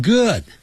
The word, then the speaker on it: good: I'm good. Thank you. 那个 G-O-O-D, 永远记住 good 跟那个书啊，一本书 b-o-o-k book 发音是一样的，不要念成 book good 那就不对了。